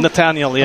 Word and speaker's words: Netanel: Netanel, yeah.